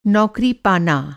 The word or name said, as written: पाना